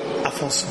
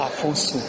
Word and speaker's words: Afonso: Afonso. – What? – Afonso. – Afonso. How do you spell it? – A-F-O-N-S-O.